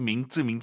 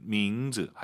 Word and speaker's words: 名字: 선생님 정말 많은 초급자분들께서 민츠 쌍지읒으로 많이 발음을 하세요. – 也能听懂吧。 – 알아들으니까 아무도 그걸 고쳐주지 않으니까 우리가 계속 민츠 하는 거예요. 제대로 하셔야죠. 민츠 제 학생 제 청취자분들이라면 민츠 하시기 바랍니다.